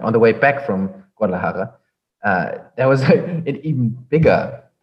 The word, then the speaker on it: Guadalajara: The funny thing is, like this was on the way to Guadalajara, and on the way to Guadalajara, there was already like I think Benny was driving on the highway at night. – I just want to comment on your pronunciation there. That was delightful.